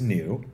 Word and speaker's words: NIU: Well, the name is NIU. That's the anglification of it.